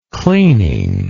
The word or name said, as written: cleaning